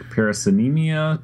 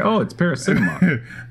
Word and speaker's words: Paracinema: Can I just say, when I was looking at the notes, I tried like eight different ways of pronouncing that in my head before Paracinema occurred to me. I was like, Paracinemia, Paracinema, it's Paracinema.